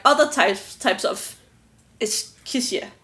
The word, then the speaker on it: Escherichia: Escherichia? – Escherichia.